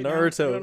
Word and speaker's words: Naruto: Naruto.